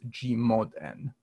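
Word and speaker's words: G/N: We write it as G slash N, and it's pronounced G mod N, because in a sense, we're modding out things.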